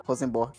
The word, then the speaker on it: Rosenborg: Rosenborg.